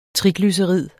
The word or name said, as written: triglycerid